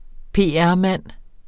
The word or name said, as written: PR-mand